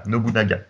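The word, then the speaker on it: Nobunaga: Non, Nobusama. Non, non, non, non, Nobu. Euh, Naga. voilà. Nobu Naga, qui, était excellent.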